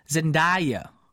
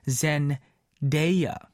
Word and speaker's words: Zendaya: She goes, oh, a lot of people say Zendaya, but it's actually pronounced Zendaya, okay? Not Zendaya, Zendaya. So there you guys go. It is Zendaya. That's how you say her name.